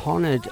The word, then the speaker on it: honoured: But Leicester City Council have honoured Laurel Aitken. My wife says you don't pronounce the H in honoured.